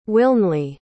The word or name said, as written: Wilnely